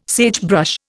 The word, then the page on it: sagebrush: Indian